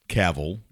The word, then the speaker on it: Cavill: Henry Cavill, rhymes with travel, yeah.